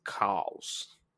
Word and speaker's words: chaos: but they don't say chaos. It's spelled chaos, but they pronounce it cows. And so every time she says chaos, it's cows, and it's awful.